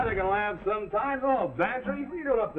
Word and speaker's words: Bantreagh: Sounds like Cloud, but it's spelled M-A-C-L-E-O-D. Well, his is Bantry. Go on, spell it. Pantry, it sounds like pantry. – I ain't no gambler, Mac. – Well, get this, for free. It sounds like pantry, but it's spelled B-A-N-T-R-E-A-G-H.